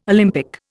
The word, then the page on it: Olympic: Indian